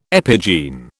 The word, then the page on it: epigene: British English